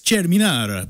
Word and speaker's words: Cerminara: Well, if you want to be insufferably as Italian as my father, it's Cerminara, but I can't.